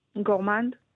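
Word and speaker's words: Gourmand: את זה, כן, צריך לדייק, וזה גורמונד, גורמן, שלום. – אהלן, אהלן. – שניכם כאן, אז איך אומרים את שם התחרות? – גורמנד. – גורמנד. – גורמנד פשוט, מבטאים את הדי בסוף, חשבתי שהצרפתים לא ירשו את זה לעצמם, אוקיי.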